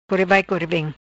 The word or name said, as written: Core Vai Core Vem